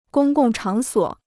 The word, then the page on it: 公共场所: gōng gòng chǎng suǒ